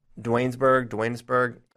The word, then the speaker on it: Duanesburg: It's not shocking in that, you know, he's from, I think it's pronounced Duanesburg, New York. – Duanesburg, New York.